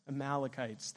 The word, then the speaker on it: Amalekites: Amalekites? Amalekites, thank you.